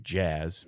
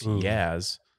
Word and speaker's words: Jazz: There's a golfer named, I believe, Jazz. Maybe it's soft J. It's Yaz.